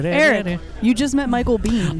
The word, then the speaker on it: Biehn: Well, you know the real way to pronounce his name is Biehn.